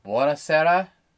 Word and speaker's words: Buonasera: And its name is pronounced. Buonasera, Mrs. Campbell. Yeah, there you go.